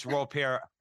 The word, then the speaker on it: Raupert: For the sake of the interview, it's Ropert, R-A-U-P-E-R-T. Because I know, you see, our wives are sisters, as you know, right? And they both speak French. So if my wife saw me try to pronounce that and I said Ropert, she'd hit me in the head with a rolling pin. – We better use it's the Ropert. Real French pronunciation then.